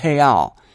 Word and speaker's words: real: The real. It's spelled R-E-A-L, but it's real. The plural is reais.